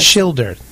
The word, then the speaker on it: Schilder: I think they say Schindler or something, but yes. Schilder.